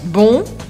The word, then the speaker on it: bon: A única diferença é do masculino para o feminino que a gente muda a pronúncia. Bon, bonne.